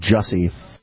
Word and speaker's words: Jussie: It's pronounced Jussie.